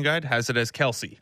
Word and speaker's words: Kelce: Well, my family pronounces it Kels.